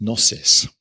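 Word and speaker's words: gnosis (γνῶσις): It's got a silent G at the beginning, so you might read it as gnosis, but don't. It's gnosis.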